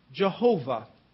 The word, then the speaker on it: Jehovah: The letters in English are YHWH, or it could also be JHWH. We would pronounce it Jehovah.